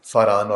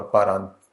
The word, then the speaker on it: فاران: تو فاران اور پاران انگریزی میں پی اے آر اے این اس کا سپیلنگ ہے, اور عربی میں فے کہتے ہیں پے کی بجائے.